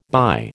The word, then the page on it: by: American English